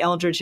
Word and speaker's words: Eldridge: Well, my name is Tori Eldridge, and it's spelled TORIELDRIDGE.